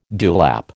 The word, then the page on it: dewlap: American English